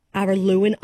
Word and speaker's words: Araluen: So if you like what you hear, you can see him on Friday, the 7th of August at Araluen Arts Centre in Alice Springs. – Nice. – Is that how you pronounce it? – Yep, Araluen Arts Centre. That's right.